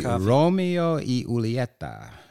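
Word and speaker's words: Romeo y Julieta: The Romeo E. Julieta, which is the correct way to say it, I've enjoyed.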